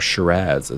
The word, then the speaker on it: Shiraz: or Shiraz, as I've learned that the local Australian people pronounce it.